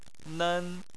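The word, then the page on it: nen: tone 1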